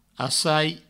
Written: assai

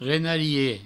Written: renarie

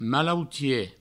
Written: malautie

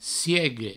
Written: siegue

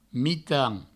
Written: mitan

